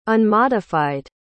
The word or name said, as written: unmodified